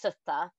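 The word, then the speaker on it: soote: it's spelled different, but it is actually pronounced sutta. Yep. It is an auditory cognate.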